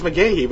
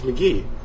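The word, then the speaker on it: McGehee: And offensively, ever since they traded Casey McGee. I always called it McGahee for some reason. That's what it looks like. It looks like it's McGahee, but it's McGee.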